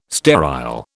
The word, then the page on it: sterile: British English